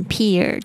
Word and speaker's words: peel: 注意一下，这个 e e 的发音是长音的这个 e，还有这 l 是翘舌音，不要卷舌起来，是 o 的声音，好像舌头抱着一颗乒乓球，呃，发出来的声音，不要念成 peel，就会变成另外一个字 peel。